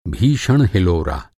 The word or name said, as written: हिलोरा